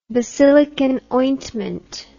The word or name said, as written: basilicon ointment